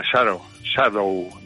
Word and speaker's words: Shadow: Uy, me ha salido la pronunciación a la americana, Shadow, diría un inglés, ¿no?